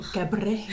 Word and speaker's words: Cabré: Donc, Confiteor de Jaume Cabré. Je sais pas exactement comment ça se prononce. Jaume hein. Cabré.